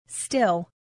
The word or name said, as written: still